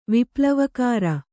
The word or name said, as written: ವಿಪ್ಲವಕಾರ